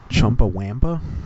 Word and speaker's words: Chumbawamba: Chumbawamba? – Yeah.